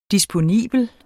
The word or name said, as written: DISPONIBLE